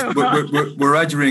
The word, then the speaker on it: Wiradjuri: Have I got my pronunciations right? – Close enough, sister. – Okay. It's Wiradjuri and Gamilaroi, but you have to grow up speaking it, you know, to get your mouth around that.